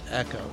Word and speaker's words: Ico: There's no way that you spell ICO and you pronounce it Echo. It's Ico. – But the developers did.